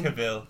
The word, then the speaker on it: Cavill: How do you say his last name? – Cavill. – Henry Cavill over Ben Affleck.